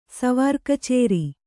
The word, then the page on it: ಸ್ವಾರಕಚೇರಿ: svāra kacēri